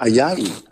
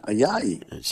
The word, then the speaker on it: Ayayi: Uh, Ayayi. – Ayayi.